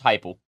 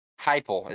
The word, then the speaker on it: Heupel: Josh Heupel. – Heupel is how you pronounce that.